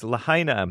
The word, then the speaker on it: Lahaina: Through Lahaina, I guess it's pronounced. I used to call it Lahaina, but it's Lahaina, Maui.